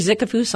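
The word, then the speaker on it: Zickefoos: Yes, Julie Zickefus on Blogspot, and that name is spelled Z as in zebra, I-C-K-E-F as in Frank.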